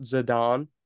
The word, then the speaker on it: Zidane: First of all, it's pronounced Zidane, apparently. I think that's fucking stupid. Z-I-D-A-N-E. – Zidane. A-N-E, that's Ain.